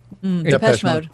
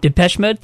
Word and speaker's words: Depeche Mode: I apologize if I butcher this, and especially if anybody likes this band. Depeche Mode? – Depeche Mode. – Depeche Mode, thank you.